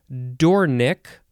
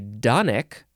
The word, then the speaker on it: dornick: The next word is Dornick, or some people say Donick, I guess.